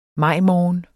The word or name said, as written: majmorgen